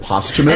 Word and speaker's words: posthumous: George Carlin also corrected your pronunciation of posthumous. – Yes, well. – Because you kept saying posthumous to him, and he's like, isn't it posthumous?